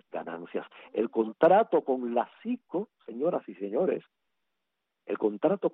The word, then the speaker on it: SEECO: SICO. Se escribe SEECO, SICO.